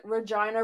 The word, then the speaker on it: Regina: And then he's like, Regina rhymes with fun.